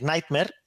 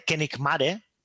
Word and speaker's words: Nightmare: Yo muchas veces me tengo que corregir y decir Nightmare en lugar de Kenikmare, que era como se pronunciaba en la época, ¿sabes?